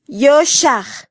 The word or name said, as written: yoxaj